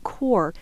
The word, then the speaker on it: corps: The phrase Core of Discovery, can you talk about the word core? Our listeners may think it's spelled quite differently than they're hearing. – Oh, sure. Yeah, core is spelled C-O-R-P-S in this case, like the French word for body.